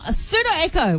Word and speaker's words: Pseudo Echo: And we are going to play some Funky Town into Pseudo Echo with a silent P.